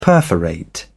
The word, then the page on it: perforate: British English